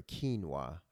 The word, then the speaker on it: quinoa: Or quinoa, as it's actually pronounced.